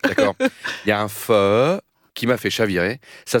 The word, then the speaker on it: pho: Alors là, on est au nord du Vietnam et on va goûter un pho. C'est comme ça qu'on prononce pho? – Pho. – Pho. – Pho. – P-H-O.